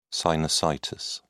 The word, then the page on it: sinusitis: British English